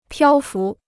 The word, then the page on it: 漂浮: piāo fú